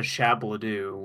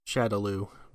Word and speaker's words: Shadaloo: Well, I mean, that makes sense because they call it Shadow Law instead of Shabladoo, which is not how you say it. – Shadaloo, but yes.